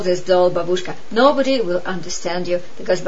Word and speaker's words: babushka: Babushka in Russian, or its correct pronunciation is babushka, means granny.